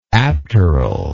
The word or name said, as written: apteral